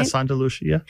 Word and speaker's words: Santa Lucia: The first time that I met you, or the second time, you invited us to your Santa Lucia party. Am I saying that right? – Yeah, Santa Lucia, yeah.